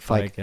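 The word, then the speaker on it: Fieg: Ähm, äh, Ronnie Fick oder Ronnie Fike, ich weiß nicht, wie, wie spricht man den Feig. eigentlich aus? Fick? Ja, oder Feig. Feig, ja, so.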